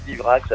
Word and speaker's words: Divrax: c'est bien Divrax avec le D à l'allemande et non pas Die à l'anglaise.